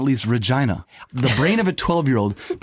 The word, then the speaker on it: Regina: It's pronounced Regina, like vagina.